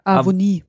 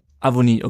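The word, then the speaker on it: Awoniyi: Avonie. – Avonie, okay, also die, ist das I, Y, I wird einfach lang ausgesprochen.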